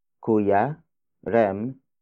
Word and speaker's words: Kuya: Bumili si Kuya Rem ng laruan para sa anak niya.